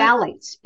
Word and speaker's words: phthalates: Phthalates.